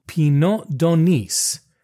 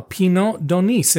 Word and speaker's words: Pineau d'Aunis: It's P-I-N-E-A-U, and the d'Onis is D'A-U-N-I-S.